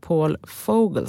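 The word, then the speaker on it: Paul Vogel: Utan under torsdagskvällen så kom ju beskedet att även finanschef Paul Fogel, ska man faktiskt uttala det, också får lämna.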